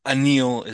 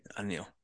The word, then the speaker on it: Anil: Anil is how you pronounce. – Okay. – Anil.